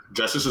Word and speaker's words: Justus: Yeah, Justice is my real name. Yeah, J-U-S-T-U-S. Yeah, that is so cool. Yeah. – It's spelled like just us, the way it's spelled exactly like that, because it's just me and my mother live together only.